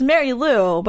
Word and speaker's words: Marie Lou: I'm like, oh, they're not gonna get it because it's Mary Lou, but it's spelled Marie Lou, right? – And I've gotten Marie Lou, mm-hmm.